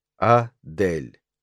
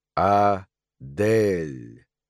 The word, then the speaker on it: Адель: Так же, как ее смутно-величественные манеры, ее, до какой-то степени, благородное происхождение — и ударение на средней гласной ее имени. Адель. Адель.